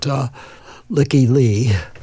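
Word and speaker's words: Lykke Li: And I also like the first song, and I'm not sure how to how she pronounces her name, but I'm going to pronounce it Lykke Lee, L-Y-K-K-E. And the last name is L-I.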